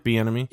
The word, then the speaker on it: Bieniemy: I mean, I see, that's the piece I don't kind of understand, is some of these people like Bieniemy. Did I pronounce that correct? – Yeah, Eric Bieniemy.